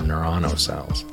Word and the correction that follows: neuronal: I can never pronounce that one correctly. Neuronal. It's a fun word to look at.